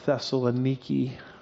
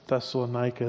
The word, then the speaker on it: Thessaloniki: And it's kind of a bummer when you actually go there and figure out they don't pronounce it at all like we do. They call it Thessaloniki. And that's painful because I've been saying it wrong all these years.